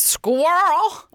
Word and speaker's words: squirrel: Like, it rhymes with Professor Quirrel. Squirrel.